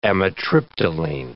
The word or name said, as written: amitriptyline